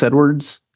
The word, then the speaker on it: Edwards: My name is Nicholas Edwards, N-I-C-H-O-L-A-S, Edwards, E-D-W-A-R-D-S.